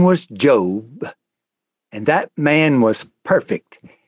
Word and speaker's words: Job: His name is spelled the same as our present-day word Job, J-O-B, but it's pronounced Job.